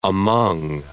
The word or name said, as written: among